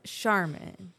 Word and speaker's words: Sharman: Yes, so it's Sharman. – And mine is Sherman, but hers is spelled with a whole lot more letters and stuff.